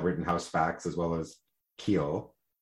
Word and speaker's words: Khill: Am I pronouncing it right? It is just K-H-I-L-L, Keel.